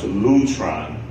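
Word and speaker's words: lutron (λύτρον): And that word ransom is a Greek word pronounced lutron.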